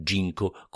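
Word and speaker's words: ginkgo: Le chiederei se anche lei viene fin qui perché le piace il ginco che si pronuncia Ginko come l'ispettore nemico di Diabolic, ma che si scrive correttamente Ginko con la G in mezzo.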